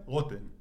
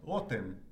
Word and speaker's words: Rotem: Rotem. – How do you say it? – Rotem.